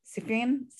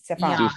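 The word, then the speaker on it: Sufjan: sufan um, am i pronouncing that right no Sufian, sufyan sufyan like Jan.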